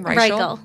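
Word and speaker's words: Reichl: I'm reading this book called Save Me the Plums by Ruth Reichel. – Oh, I don't know how to pronounce it. Is that how you pronounce her name? – Reichel. Yeah.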